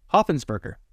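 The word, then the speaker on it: Hopfensperger: Hoppensperger. – Oh, that's easy. – Yeah, it's like 4 syllables, but there's so many letters, it just makes it impossible to really understand.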